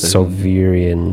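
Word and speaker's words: Sovyrian: Sarivian, but it's Sovirian. – Sovirian? – Sovirian. – Sovirian. – Yeah, Sovirian. – S-O-V-Y-R. Sovirian. – Sovirian.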